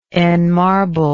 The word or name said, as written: enmarble